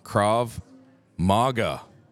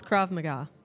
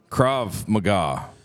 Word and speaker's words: Krav Maga: With us today, my friends, I don't know about Mike's, I've got from Pure Krav Maga. Did I say that right? – Close. Krav Maga. – Krav Maga. – Yes.